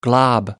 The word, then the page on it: glob: American English